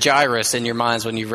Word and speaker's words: Jairus: How you pronounce J-A-I-R-U-S, I have always pronounced it Jairus. And then as I heard Scott read and he pronounced it differently than me, I happened to have my phone sitting there because my watch band broke, so I needed it for time. I quickly Googled, how do you pronounce this name? And apparently the correct pronunciation is Jairus.